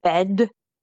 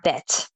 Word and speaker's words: bed: So make sure you pronounce that D as a T there. So it's not bed, maar bed.